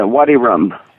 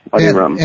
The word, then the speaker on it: Wadi Rum: Now, I have the impression that you named your film company Wadi Rum. Am I saying that right? – Yeah, Wadi Rum. Wadi Rum.